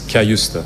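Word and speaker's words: Cajuste: But I say Jens Cayuste is how I would say it.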